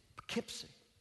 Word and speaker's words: Poughkeepsie: It's like a lady came to me in one of my, I was preaching in, how do you say it? Poughkeepsie. That's it, I got it right. Poughkeepsie, New York.